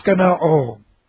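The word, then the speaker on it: skenoo: We're going to give this S-K-E-N-O and then a long O. Skeno-O.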